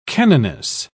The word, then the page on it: canoness: British English